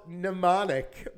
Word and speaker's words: Mnemonic: It's called Mnemonic, Mary. – No, the first letter is an M. Johnny Mnemonic. – I know, and that's how you spell Mary. That's how you spell Mnemonic.